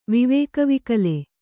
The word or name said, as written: ವಿವೇಕವಿಕಲ